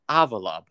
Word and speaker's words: Avila: It's pronounced Avila, by the way.